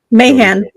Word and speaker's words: Mayhan: Mayhan.